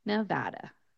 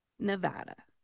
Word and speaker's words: Nevada: what you want to do is keep your jaw relatively closed when you are pronouncing the A's in the word. You're going to pronounce it more with an American type A than with a Spanish or open vowel sound type A. So when you pronounce it, you are going to say Nevada. Nevada.